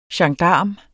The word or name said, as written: gendarm